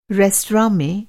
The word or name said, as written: रेस्तरां